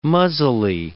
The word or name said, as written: muzzily